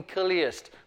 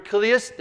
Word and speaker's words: Chiliast: What is widely known about Papias is that he was an outspoken Kiliast. By the way, that is what we are as a church. We are Kiliasts. That's from the Greek word. You spell it C-H-I-L-I-A-S-T.